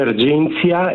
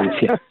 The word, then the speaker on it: Argentia: Si dice Argentia e si scrive alla latina con la T, quindi okay, sala allora... Argentia.